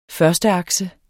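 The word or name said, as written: førsteakse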